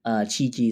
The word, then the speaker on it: Chiichi: Chiichi is spelled C-H-I-I-C-H-I, a blind male musician of Heike who lived from the early 18th century through the turn of the 19th century.